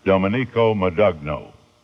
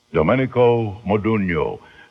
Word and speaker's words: Domenico Modugno: I once heard a disc jockey, a new disc jockey, young fella, hadn't been around too much, pronounce that Domenico Modugno. But as you know, it's Domenico Modugno.